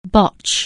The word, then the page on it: botch: anglais